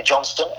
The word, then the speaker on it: Johnston: So my name is Antony Johnston, and it is spelled AntonyJohnston.